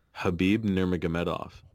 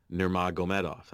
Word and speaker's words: Nurmagomedov: Say his last name again. – Khabib Nurmagomedov. – Whoa. – Nurmagomedov. That's how it's pronounced. – Nice. That's good pronunciation.